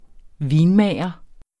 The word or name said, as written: vinmager